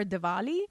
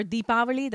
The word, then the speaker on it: Deepavali: They just put out a stamp 2 years ago for Diwali. Or Deepavali, that's the right way to pronounce.